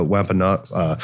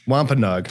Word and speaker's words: Wampanoag: So he had to go and live with another tribe, the Wampanoag. I can't pronounce it. I'm sorry. – No, you hit it. You hit a home run on that one.